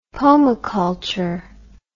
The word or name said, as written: pomiculture